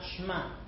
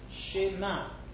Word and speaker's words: Shema: So it's going to be shema. It's not shma. Shema.